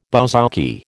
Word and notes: bouzouki: American English

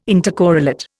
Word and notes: intercorrelate: Indian

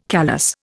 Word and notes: callous: Indian

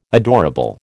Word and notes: adorable: American English

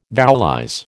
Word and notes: vowelize: American English

